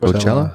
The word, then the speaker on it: Coachella: Ik weet nog, omdat ik in die periode heel veel aan het draaien was, dat hun optreden in 2006, ehm, in Coachella, hoe spreek je eigenlijk uit? – Coachella. Coachella, was de allereerste keer dat er eh, met een dergelijk systeem gedraaid werd.